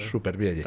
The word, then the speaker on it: Supervielle: Nosotros lo hemos españolizado ya y lo pronunciamos tal cual. – Supervielle. – Tal cual se lee, sí.